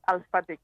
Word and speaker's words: Alphatic: Con PH, o sea que lo pronunciamos como ha empezado hoy el programa mi compañera Andrea diciendo Facebook, pues esto diremos que es Alphatic con H intercalada.